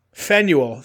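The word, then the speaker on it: Faneuil: Fenuel? – Fenuel.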